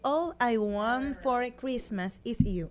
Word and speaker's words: All I Want for Christmas Is You: Que hace casi 28 años salió esta hermosa canción, esta composición de Mariah Carey, de All I Want for Christmas Is You.